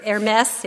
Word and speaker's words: Hermes: And Barnabas they called Zeus, and Paul Hermes, or Hermes, if you want to say it like the store, because he was the chief speaker.